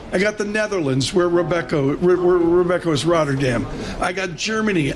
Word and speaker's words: Robeco: Depends if you're Dutch. It's Robeco.